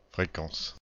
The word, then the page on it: fréquence: /fʁe.kɑ̃s/